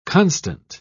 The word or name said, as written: constant